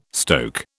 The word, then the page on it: stoke: British English